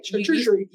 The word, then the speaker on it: charcuterie: Or if you make a charcuterie board, which I said it so right, charcuterie.